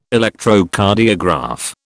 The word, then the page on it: Electrocardiograph: British English